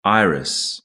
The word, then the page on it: iris: British English